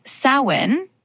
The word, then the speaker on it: Samhain: So Samhain, pronounced a bit like salmon, is actually an ancient Celtic festival, not a person.